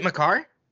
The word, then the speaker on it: Makar: Is it Makar? – Oh, it is spelled M-A-K-A-R. – So it's Makar. It has to be Makar.